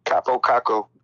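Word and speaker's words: Kaapo Kakko: How the hell do you pronounce his name? – Capo Caco.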